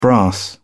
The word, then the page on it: brass: British English